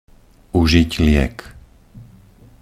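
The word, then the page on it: uzit liek: Slovak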